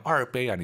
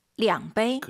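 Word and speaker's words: 량베이 (两杯): 두 잔은 뭐예요? 얼베이 아니죠? 우리도 2잔이라고 안 하잖아요. 두 잔. – 량베이.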